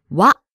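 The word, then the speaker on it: wa (は): This is pronounced wa But if you look at it, it is not the hiragana character for wa. Do you know what I mean? – Ah, yes, of course. It's actually the character for ha. – So, so, so. – But don't let this confuse you. – Yes. When writing the particle wa, you write it using the character for ha. It's still pronounced wa, though.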